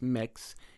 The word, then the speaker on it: Mx: So I've seen MX, or I believe it's pronounced mix, used in email signatures,